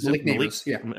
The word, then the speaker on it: Malik Nabers: teams are going to be putting a lot of emphasis on trying to stop Malik Nabers. Sure. Did I say his name right? Is it Malik Nabers? Okay. – Yeah.